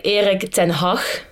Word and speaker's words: Erik ten Hag: Yeah, so in Dutch, we say Erik ten Hag.